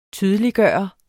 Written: TYDELIGGJORDES

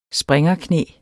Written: springerknæ

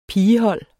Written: pigehold

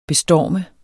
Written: bestorme

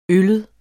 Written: øllet

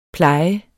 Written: Plejen

